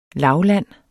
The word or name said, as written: LAVLANDENE